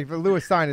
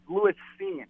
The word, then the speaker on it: Lewis Cine: So, well, the funny thing is that you're pronouncing it wrong with even their first round draft pick. It's Lewis Cine.